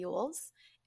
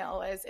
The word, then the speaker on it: Elwes: For a good portion of my life, I thought that it was pronounced Cary Elwes. It's not, it's Cary Elwes.